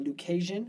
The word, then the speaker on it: Lucasian: And a few years after that, he gets elected to be a Lucasian.